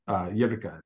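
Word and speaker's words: Røkke: Jerka is the last name. It's spelled R-O-K-K-E. I did some, you know, pronunciations listening to it to try to, you know, I still probably mangled it.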